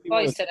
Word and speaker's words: Heuser: Miguel, Heuser. Apliquemos el alemán que hemos aprendido, Heuser.